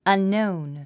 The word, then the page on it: unknown: ʌnnóun; アンノーン